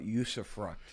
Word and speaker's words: usufruct: Talk about usufruct. Am I even saying it right? – I think you're saying it right.